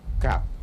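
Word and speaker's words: kab: Que en lengua yocotán se pronuncia cap.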